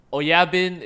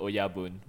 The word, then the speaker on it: Oyabun: Yeah, it is like if you're in the Yakuza, you would like call your like the chairman like Oyabun, like basically your grandfather. – Wow, Lin's got a clean signature. – Oyabin is a mispronunciation of the word Oyabun, which means boss.